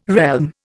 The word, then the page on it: realm: Indian